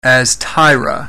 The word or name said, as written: Astyra